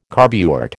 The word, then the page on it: carburet: American English